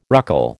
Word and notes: ruckle: American English

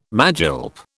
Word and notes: magilp: British English

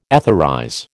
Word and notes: etherize: American English

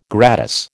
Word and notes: gratis: American English

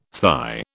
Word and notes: thigh: British English

